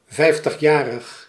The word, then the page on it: 50-jarig: /ˈfɛiftəxˌjarəx/; 4 lettergrepen